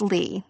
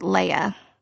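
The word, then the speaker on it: Leigh: It's Lee, spelled L-E-I-G-H. I pronounced it Leia, like the princess.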